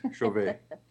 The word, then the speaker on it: Chauvet: It is called, pronounced the Chauvet Cave, is it? Is that correct? – Yes, sir. I mean, my French is horrible, but I believe, yes, Chauvet.